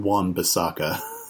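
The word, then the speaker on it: Wan-Bissaka: And there was a red card in the 75th minute from Aaron Van Bissaka. – Could you butcher that anymore? – Yes. – Literally phonetic. It's Aaron Van Bissaka.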